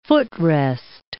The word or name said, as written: footrest